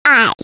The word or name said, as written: eye